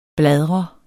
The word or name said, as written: bladre